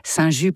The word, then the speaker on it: Saint Just: Quant à Saint Just, prononcé Saint Just par les Lyonnais, ce fut le 13e évêque de Lyon au IVe siècle.